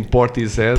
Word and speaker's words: Portishead: Acho que é importante falar também, porque passei anos, e penso que vocês também, a chamar a banda Portishead, até descobrir que não é a pronúncia correta, deveria ser sempre assim, Portishead.